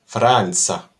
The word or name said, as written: franca